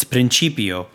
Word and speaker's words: Principio: The third error we're going to be fixing is in the Gloria, where I was saying Principio as Principio. It's Principio, and there's no E there, it's an I, so it has to be pronounced Principio. So that's the third one, and then the fourth one was just a complete butchering of the word.